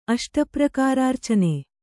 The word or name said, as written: ಅಷ್ಟಪ್ರಕಾರಾರ್ಚನೆ